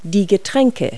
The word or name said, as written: beverage